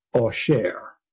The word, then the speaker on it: Auxerre: So he is in a place called Ossher. Well, what is Ossher? A-U-X-E-R-R-E, right? So that is the French pronunciation.